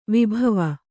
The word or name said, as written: ವೈಭವ